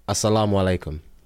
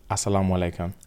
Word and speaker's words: assalamu alaikum: Can you teach me how to say it? – Assalamu alaikum. – Assalamu alaikum. – And then you would say wa alaikum salam.